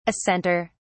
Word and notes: assenter: British English

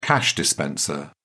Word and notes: cash dispenser: British English

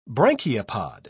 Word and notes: branchiopod: British English

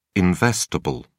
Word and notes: investable: British English

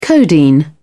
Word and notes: codeine: British English

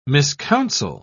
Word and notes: miscounsel: British English

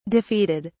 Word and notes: defeated: British English

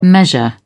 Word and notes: measure: British English

